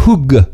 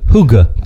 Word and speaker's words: hygge: Le mot, il s'écrit H-Y-G-G-E, mais il se prononce Hug. Hug. – H, mettons H-O-O-G-A, euh, mettons Hug.